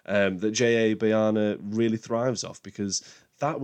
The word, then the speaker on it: Bayona: Bayona, and just a quick one, I don't know why I always get Bayona's name wrong. I called him, I think, Boyana, I think, on my Jurassic World podcast.